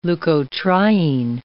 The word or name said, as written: leukotriene